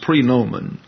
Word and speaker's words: praenomen: That's praenomen, pronounced praenomen.